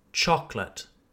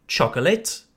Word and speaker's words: chocolate: The real pronunciation then is chocolate. We don't pronounce the second O, just chocolate. Do you also hear how an English CH is a ch sound? It's like a steam train, un train vapeur. Ch, ch, ch, ch. This word has two syllables and we emphasize the first syllable.